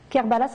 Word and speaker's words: Kerbala: Kerbala, ça s'écrit K-E-R-B-A-L-A.